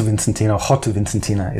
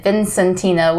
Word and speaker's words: Vicentina: And yeah, you might be tempted to say Rota Vicentina or something like that, but Rota Vicentina is more the way that you pronounce it.